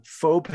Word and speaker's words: faux: No, I'm going to pronounce it the French way. – Ooh. – Faux Paris.